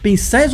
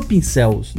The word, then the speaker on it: pincéis: Pincéis ou pincéus? Não sei se eu falei certo. Pincéis.